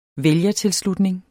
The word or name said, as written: vælgertilslutning